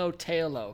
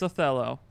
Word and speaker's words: Othello: The common thing is he's just saying it's a long O-tail-o, because he's just like adding O's and shit to words. – Nope. It's Othello.